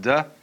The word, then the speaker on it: Te: And the book is called the Tao Te Ching, because again, there's no apostrophe after the T, so it's pronounced de, and Jing.